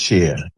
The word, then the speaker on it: Shia: And it's even pronounced Cher. You know, it's like the Cher Muslim, S-H-I-A, and Cher, Bono, Cher, whatever, is exactly pronounced the same way.